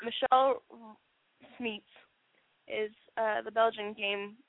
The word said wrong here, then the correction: Michel: Am I saying that wrong? Because that's a dude. Michel. Anyway. – Michel. – right. Make it sound French.